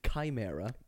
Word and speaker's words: chimera: But I'm going to go with Chimera is an ancient Greek creature consisting of what three animals? – I think the pronunciation is chimera.